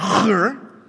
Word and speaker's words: Hur: He fought with Amalek while Moses, Aaron, and Hur, if you would like to pronounce that the Hebrew way, it helps to cough.